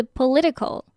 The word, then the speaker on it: political: 前面三个字 most of our 要连在一起，然后这个 of 它虽然是 f 结尾，可是你可以念作 v 的音 of most of our，然后下一个字 political。 Political，有没有发现 political 这个字它虽然有 t 这个字母，可是这个 t 它会念作 d 的音，变成 political。